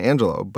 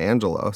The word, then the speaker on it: Angelou: I didn't know Maya Angelou's name is actually pronounced Maya Angelou, but the engineer Sharon actually worked on her book and it's Maya Angelou. So I'm now going to be an evangelist for Angelou as a pronunciation.